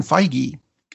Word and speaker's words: Feige: Speaking of other things that are on their way, Kevin Feige, and yes, I am pronouncing it right because I called Marvel and I asked them,